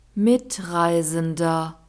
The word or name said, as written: Mitreisender